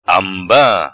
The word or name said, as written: Amba